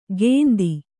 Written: ಗೇಂದಿ

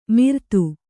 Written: ಮಿರ್ತು

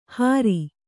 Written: ಹಾರಿ

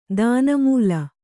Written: ದಾನಮೂಲ

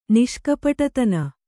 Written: ನಿಷ್ಕಪಟತನ

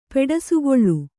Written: ಪೆಡಸುಗೊಳ್ಳು

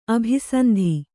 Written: ಅಭಿಸಂಧಿ

